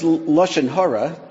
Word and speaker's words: lashon hara: But the phrase lashon hara, to give it the Yiddish pronunciation, is used fairly broadly by all sorts of folks.